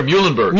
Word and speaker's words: Muhlenberg: I may butcher the name, Mecklenburg? – Peter Muhlenberg. – Muhlenberg, thank you.